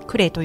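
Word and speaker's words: 久礼: あのクレという日本酒がありまして、うんうんうん、久しい礼儀の礼でクレと読むんですけれども。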